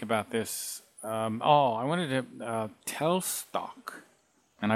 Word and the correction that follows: Teilstück: Well, it has the word tile, I think tile, and then Stück. Stuck. I think, right? – Teilstück.